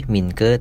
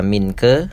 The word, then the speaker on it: Minke: Buku ini berkisah tentang perjalanan seorang laki-laki warga negara Indonesia yang namanya Minke di bukunya ditulisnya M I N K E Minke tapi kalau bacanya yang benar itu adalah Minke pakai G buat yang masih baca Minke